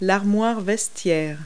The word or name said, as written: locker